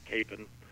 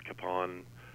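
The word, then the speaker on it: Kapaun: The proper pronunciation is Capon, and from my understanding, the difference in the pronunciation is the difference between it being a Czech pronunciation and a German pronunciation. That the Czech pronunciation of it is Capon, and the German pronunciation would be Capon.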